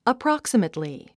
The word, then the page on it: approximately: əprɑ’ksəmətli; アップロォクサメトリィ